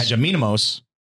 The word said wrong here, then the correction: Hajidemos: I think I said it right. – Hajidemos. – Hajidemos.